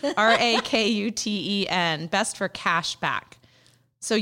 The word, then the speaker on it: Rakuten: Rakuten? – Rakuten.